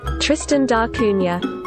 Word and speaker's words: Tristan da Cunha: Tristan da Cunha. – Tristan da Cunha.